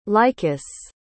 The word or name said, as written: lycus